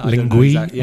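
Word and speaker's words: Linguee: One is, I don't know how to pronounce it, I think it's Lingue or Lingue. – Yeah, or Lingui, yeah. – Lingui, L-I-N-G-U-E. double E, lingui, yeah.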